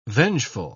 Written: vengeful

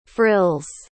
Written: frills

